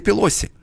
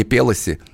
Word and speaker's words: Пелоси: которая правильно произносит не Пелоси, как многие другие говорят, а Пелоси. У нее итальянская,